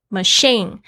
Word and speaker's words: machine: Machine 是这个机器，M A C H I N E machine，注意一下那个 C H 的发音是发 sh 的声音。